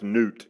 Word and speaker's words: Nut: For example, it's spelled Nut, but I think it's pronounced Newt. She was the goddess of the heavens in Egypt. Her husband was Geb, G-E-B.